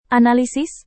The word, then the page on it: analisis: indonesian